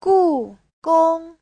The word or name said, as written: gu4gong1